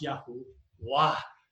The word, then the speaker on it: Yahuwah: Greek transcriptions also indicate that YHWH should be pronounced Yahuwah.